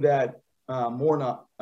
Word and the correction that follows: Murnau: uh, that uh, the director, if I don't look at it, I will mispronounce it. Murnau, uh, F.W.